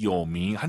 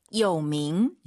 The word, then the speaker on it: 有名: 한자를 좀 아시는 분들이라면 이 요밍 한자 보시자마자 뜻을 아마 아셨을 거예요. 근데 嗯哼。 – 발음을 잘하셔야죠. 삼성이성 아아 한번 더 들어볼게요. – 有名。